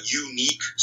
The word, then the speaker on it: YouNeek: One that comes to mind is Unique Studios, and that's spelled Y-O-U-N-E-E-K, Studios, which is an African company.